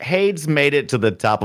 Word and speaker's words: Hades: No, it's Hades. Yeah. One syllable.